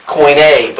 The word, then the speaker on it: Koine: Koine is kind of a funny way of pronouncing. If you were to do it, we'd say coin, and then put a coin A. How would you say A? Like this, Koine. But it's spelled C-K-O-I-N-E.